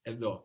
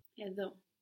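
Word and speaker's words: Edo (ἐδώ): Edo. And this is the word for here. – Edo. – Edo is spelled E, O, Epsilon, Delta. And then the O sound there is with the W shape, omega.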